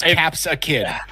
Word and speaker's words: Capsakid: Yeah, it's in the new one, Capsakid. And it's spelled exactly like that. It's Capsakid.